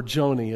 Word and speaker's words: Joni: Johnny, or Joni as some people, but Johnny I think is how it's pronounced.